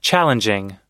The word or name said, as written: challenging